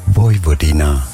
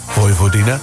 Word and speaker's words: Vojvodina: En ik heb even opgezocht hoe je het uitspreekt. – Vojvodina. – Vojvodina.